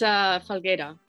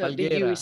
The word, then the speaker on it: Falguera: And yeah, it's Falguera. So Falguera. The U is silent.